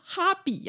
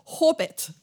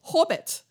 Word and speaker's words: Hobbit: 我说，可是我怎么发音都，他就不是哈比呀，他是 Hobbit，所以我会说 Hobbit 就是霍比特嘛。